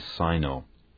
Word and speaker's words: Sino: By the way, Sino is sometimes pronounced Sino. Most Americans will say Sino.